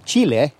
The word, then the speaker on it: Chile: Isabel was born in Peru in 1942 and raised in Chile, or Chile, to use the proper pronunciation, which I kind of skid in and out of in our conversation.